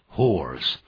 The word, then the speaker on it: hoarse: hoarse, 听起来有点哑的，这个哑这个发音很像马那个发音，这个马呢叫 horse, H-O-R-S-E, 但如果是讲这个沙哑的这个形容词呢，也就是 horse, H-O-A-R-S-E, You sound a little bit hoarse, 你听起来有点哑，刚感冒，我们就可以这样说了。